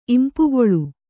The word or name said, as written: ಇಂಪುಗೊಳು